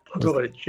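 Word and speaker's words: Pogorelić: If you've just started listening, this is Radio Maria, and the music that you were just hearing, I'm going to try and pronounce the pianist's name again, was Scarlatti, played by Ivo Pogorelic. – Pogorelic, yep. – Was that better? – Yes, not bad. Not bad at all.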